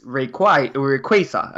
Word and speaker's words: Rayquaza: But of course, we later on that it's supposed to be from Quasar, the star. So it's Rayquaza.